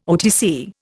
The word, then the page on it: OTC: Indian